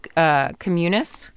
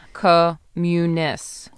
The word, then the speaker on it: communis: Communis. – Okay. – You know how you accent the second syllable typically in Latin, so that would be my guess.